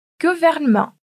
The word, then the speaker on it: gouvernement: Mas também pode aparecer quando duas consoantes precedem a vogal E. Vamos repetir comigo os exemplos, então? S'asseoir, appartement, tortue et gouvernement.